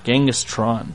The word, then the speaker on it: Genghis Tron: Genghis Tron. – Like, instead of Con, Tron.